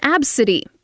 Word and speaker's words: Abcde: This is Absidy, but her name is spelled A-B-C-D-E.